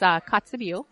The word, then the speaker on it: Kotzebue: Yes, it's Kotzebue.